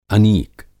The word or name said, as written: अनीक